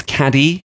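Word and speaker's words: Cádiz: He made a visit down to Cadiz in the south of Spain, spelt Cadiz for us English people who can't pronounce it properly, but I was informed reliably that it is Cadiz, which I was informed reliably when I was on a walking tour there.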